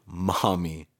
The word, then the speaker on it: Maumee: M-A-U-M-E-E, Maumee. – Maumee. Yeah, more emphasis on the me, I think.